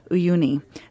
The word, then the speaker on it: Uyuni: Uyuni.